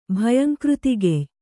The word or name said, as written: ಭಯಂಕೃತಿಗೆಯ್